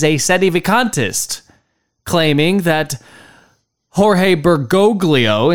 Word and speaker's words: Bergoglio: By the way, Father Altman pronounces it Bergoglio. In Italian, that second G would be silent, so it is Bergoglio.